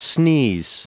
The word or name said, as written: sneeze